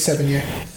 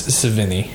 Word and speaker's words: Sevigny: I said 7 years. – I thought it was that too, but it's Sevigny.